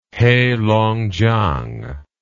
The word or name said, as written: heilongjiang